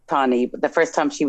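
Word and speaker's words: Tannie: Now, we say Tani.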